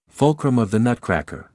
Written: fulcrum of the nutcracker

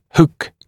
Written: hook

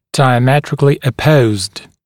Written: diametrically opposed

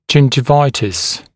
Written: gingivitis

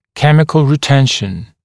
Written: chemical retention

